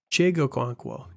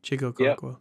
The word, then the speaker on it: Chig Okonkwo: Chig Okonkwo. You can just call him Chig. Yeah. Chig Okonkwo.